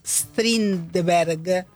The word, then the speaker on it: Strindberg: Esta obra propone una relectura de La señorita Julia, de August Strindberg, si he pronunciado bien, desplazando su núcleo dramático hacia la Argentina en el contexto de la última dictadura.